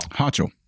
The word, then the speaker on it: Hochul: H-O-C-H-U-L. – Hochul.